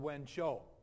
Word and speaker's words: Wenzhou: That's how you pronounce that Chinese city name. That's called Wenzhou.